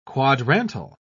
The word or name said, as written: quadrantal